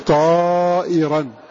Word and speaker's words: طائرا: قراءة نافع كما قال هو وهي طائرا كما تلفظ هو بها، فتكون قراءته فيكون طائرا بإذن الله، فتكون طائرا بإذني. فتكون قراءة نافع بألف وهمزة مكسوة بينهما في الموضعين طائرا.